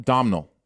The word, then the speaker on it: Domhnall: What also like Dominic, is it Dominic Gleeson who plays Schaefer? – Domhnall. I think is how you say his name. I think it's spelled Domhall. Domhnall Gleeson.